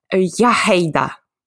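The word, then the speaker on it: Iechyd da: Это, значит, читается как «яхейда». То есть э, там в самом начале должна быть я, и там должен быть очень такой вот какой-то скрежущий такой немного звук.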